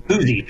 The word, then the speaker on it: Poosey: Poosie, is that how you say it? – Poosie, P-O-O-S-E-Y, Poosie Conservation Area.